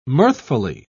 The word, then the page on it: mirthfully: British English